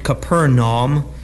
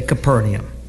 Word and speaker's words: Capernaum: When they were come to Capernaum, some pronounce it Capernaum. I just like to say Capernaum.